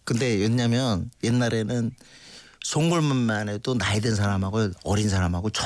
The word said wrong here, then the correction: 송골매: 송골매 발음을 좀 똑바로 해주세요.